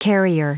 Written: carrier

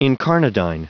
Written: incarnadine

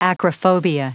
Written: acrophobia

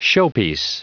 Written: showpiece

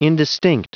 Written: indistinct

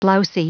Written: blousy

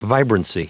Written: vibrancy

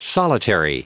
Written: solitary

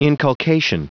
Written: inculcation

